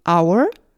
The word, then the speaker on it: hour: 예를 들어 프랑스어로 호텔은 H-O-T-E-L hotel이며 병원은 hospital, hospital이 아닌 hospital이라고 읽습니다. 이렇듯 프렌치에서 letter H가 묵음인 여러 단어들은 영어로 그대로 전해지며 오늘날 우리는 명예 honor, honor, 정직한 honest, honest, 시간 H-O-U-R, our, 상속자, H-E-I-R, heir 등에서의 H를 발음하지 않습니다.